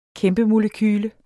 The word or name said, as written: kæmpemolekyle